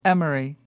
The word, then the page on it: emery: American English